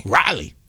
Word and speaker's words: Raleigh: We are welcoming the president of Shaw University out of Raleigh, North Carolina. If I'm saying that right. – Really? Good. Raleigh? – Raleigh, North Carolina. Yeah.